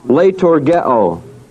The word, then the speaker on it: leitourgeo: The word minister is leitourgeo, L-E-I-T-O-U-R-G-E, long O. Leitourgeo.